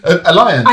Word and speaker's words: Alliance: How do you say alliance? – Alliance. – Alliance. I always said that in a Spanish way.